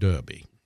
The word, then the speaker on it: Derby: But you pronounce it Derby. It's D-E-R-B-Y, but in England it's pronounced Derby. – Interesting. – As in the horse races, the Derby.